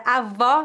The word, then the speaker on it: avó: A palavra avó com acento agudo refere-se à mãe da sua mãe ou do seu pai. A pronúncia dessa palavra é feita com o O aberto.